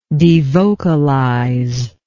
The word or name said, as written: devocalization